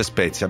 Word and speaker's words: Spetia: Mi chiedete se mi chiamo Spezia o Spetia, si scrive Spetia, si legge Spezia, abbiate pazienza, ma è così.